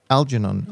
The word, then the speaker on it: Algernon: I'm sure pronouncing that right, but anyways, um, and uh, so this is this is one of those very old-fashioned British names that I think you should pronounce it Algernon. Algernon.